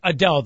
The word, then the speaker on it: Adele: I don't know if she's It's pronounced Adele, though, right? – Yes, it is.